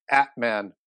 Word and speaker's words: Atman: It goes back to something that they call the Atman particle. And I said that as it's spelled, A-T-M-A-N, but it's the N's not pronounced.